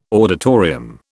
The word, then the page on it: auditorium: British English